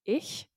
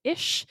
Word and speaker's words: ich: I in German is ich, I-C-H, but some people pronounce that as ish. I can't remember exactly which regions that is split by, but like I've always just pronounced it as ich, not ish.